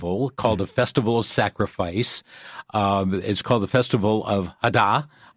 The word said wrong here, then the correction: Eid al-Adha: You've got a different pronunciation, I know. – Eid al-Adha.